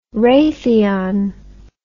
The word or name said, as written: raytheon